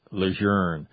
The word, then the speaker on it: Lejeune: I've learned already, Lejeune.